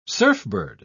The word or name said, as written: surfbird